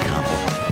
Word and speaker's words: Kamala: It's pronounced Kamala.